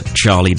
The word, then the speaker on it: Charlie: It's about a 25-second video on my Twitter feed, which is at Charlie Davey.